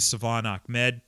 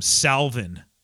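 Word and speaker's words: Salvon: So anyway, a lot of people called him Salvin yesterday. Savon.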